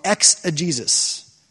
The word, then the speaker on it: exegesis: The fancy word for trying to learn what the author is saying is called exegesis. Not Jesus like, you know, Jesus, but it's like spelled with a G.